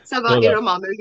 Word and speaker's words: Saba Erum Amir: Saba Erum Amir, you're right.